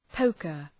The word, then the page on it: poker: ‘pəʋkər